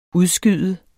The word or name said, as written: udskyde